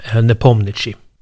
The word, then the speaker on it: Nepomniachtchi: Om man uttalar så som han skrivs, om man uttalar det liksom ordagrant, eller man ska säga, så heter han Jan Nepomniachtchi eller något sånt. Jag har försökt lyssna på hur det låter när ryssar faktiskt uttalar honom. De säger ofta Nepo, men de säger också Nepomniachtchi. Så i november i Dubai går matcherna stapeln.